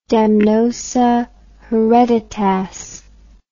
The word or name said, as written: damnosa hereditas